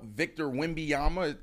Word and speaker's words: Wembanyama: You know, we want to talk sports, get an opportunity to talk about Victor Wembanyama. Did I say that correctly? – You did.